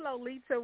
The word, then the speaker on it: Lolita: It's Lolita with a T. And when I say that, I say a T, not a D, because so many people pronounce my name Lolita all the time.